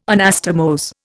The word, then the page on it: Anastomose: Indian